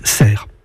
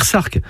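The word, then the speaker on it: Sercq: J'ajoute que la forme anglaise du nom de l'île s'écrit avec un A. Mais ce n'est pas pour cela qu'il faut dire Sarque. Ce serait ignorer la prononciation locale et la prononciation française.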